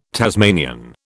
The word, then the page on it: Tasmanian: British English